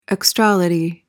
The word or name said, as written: extrality